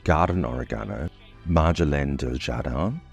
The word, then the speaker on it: Marjolaine: Marjoram in French is pronounced Marjolaine and spelled M-A-R-J-O-L-A-I-N-E.